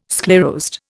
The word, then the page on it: sclerosed: Indian